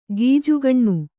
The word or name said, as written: ಗೀಜುಗಣ್ಣು